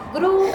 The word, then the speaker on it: grew: Gru, el pasado, se pronuncia como un personaje de una peli que se llama Gru, no sé si se acuerdan, si la han visto. El de los Minions, ¿vieron? – Ah, mi villano favorito. – Ajá. Se llama Gru, se pronuncia igual que esta.